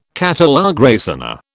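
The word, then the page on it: catalogue raisonné: British English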